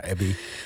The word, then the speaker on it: Abbie: Je naam wordt continu verkeerd uitgesproken. – Ja, dan ben ik gewend nu. Ja. Abi, ja, Abby.